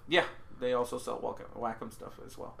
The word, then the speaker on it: Wacom: W-A-C-O-M. – Oh, Wacom. Wacom. – Yeah. – Yeah. – Well, that's how they pronounce it. The company pronounces it Wacom, so...